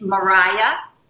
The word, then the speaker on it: Marije: They tuned in for you, and her name, I don't even know how to pronounce it. It's at M-A-R-I-J-E, Marije van der Molen from Holland, and she said she's a big fan, so just say hi to her. – I know her. It's Mariah.